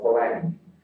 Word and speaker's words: Polanyi: The two most important writers in the tradition are a Hungarian called Karl Polanyi, I believe is the pronunciation, though in the English speaking world we would simply call him Karl Polanyi, and the historian Moses Finch.